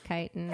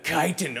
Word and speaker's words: chitin: Also, not to be nitpicky, but it's pronounced chitin. – Oh. – The chitin.